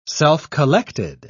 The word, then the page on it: self-collected: British English